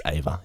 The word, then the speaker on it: Eva: It's spelled Eva, but he pronounces it as Ava, yeah.